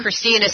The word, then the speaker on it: Christina: Yeah, and it's Christina, C-H-R-I-S-T-I-N-A, because I think sometimes people spell it somehow differently.